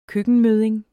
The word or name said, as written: køkkenmødding